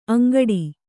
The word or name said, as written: ಅಂಗಾಡಿ